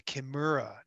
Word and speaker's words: Kamuru: and I think it's called a kimura, not not spelled like his name, but K A M U R U,